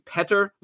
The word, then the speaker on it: Petr: Petr Mrazek, not Peter Mrazek, as I think I've been saying all season long.